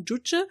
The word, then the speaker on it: Juche: Dieses Juche oder Juche? Juche. – Juche, Juche, ich habe ein Aussprachedings äh, gefunden. Juche äh, ist eine politische Ideologie, die er eingeführt hat.